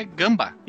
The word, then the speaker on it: Gamba: ou ele simplesmente gosta da cultura japonesa mas a pronúncia é Gamba.